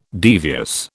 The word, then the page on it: devious: British English